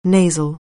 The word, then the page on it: nasal: anglais